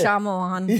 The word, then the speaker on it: Shyamalan: I would say Shyamalan. – Yeah, that's it. Yeah. I think that's right. Yeah. – It's hard not to hit the ding dong after you get through Shyamalan.